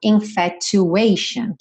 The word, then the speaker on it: infatuation: Então, lembra que esse sufixo T-I-O-N sempre vai ser pronunciado Sean, Sean. Uhum. E aqui, como a gente tem esse A antes, a gente vai ter esse Asian. Uhum. Então, infatuation, que vai rimar lá com a linha de baixo, olha só.